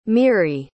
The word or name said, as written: miri